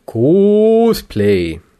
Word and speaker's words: Cosplay: Cosplay. Mhm. Das O wird nicht langgezogen. Und glaub mir, ich muss es wissen. Smiley. Ich habe das Wort erfunden. Es wird englisch ausgesprochen. Cos, wie Costume und eben Play.